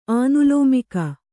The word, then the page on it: ಆನುಲೋಮಿಕ: ānulōmika